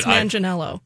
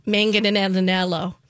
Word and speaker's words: Manganiello: I'm pretty sure that's right. Does anyone know like an official pronunciation? – I think you did. – Manganiello. – I always trip over his name, but I think that's right.